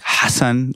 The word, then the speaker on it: Hasan: You go to certain parts of the world and they go like Hasan, like in the Middle East, you pronounce the A part of it a little bit harder.